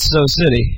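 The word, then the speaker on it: Sew City: I would pronounce it So City. – So City. – Yeah. – I was kind of hoping that it was a mispronunciation of Sioux City. – Sioux City. – You know, like, that would be... – Sioux City, Iowa? – Yeah, like, that would be cool. – Because it's spelled S-E-W, which is so.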